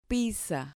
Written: pizza

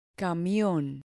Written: camion

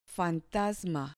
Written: fantasma

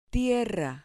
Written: tierra

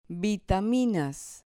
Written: vitaminas

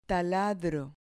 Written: taladro

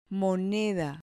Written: moneda

